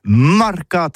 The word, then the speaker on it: marcat: Eh bien, disons à tous, mais le mercat de l'Union ou le mercat des rebelles, mais en gascogne toulousain, c'est prononcé sur les marcat, avec un A, marcat.